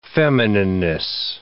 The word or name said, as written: feminineness